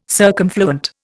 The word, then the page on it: circumfluent: Indian